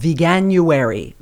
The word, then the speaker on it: Veganuary: I'm going to start out today by talking about something called Veganuary. Veganuary, Veganuary, Veganuary, Veganuary. How do you spell that? V-E-G-A-N-U-A-R-Y, Veganuary.